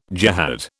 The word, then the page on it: jehad: British English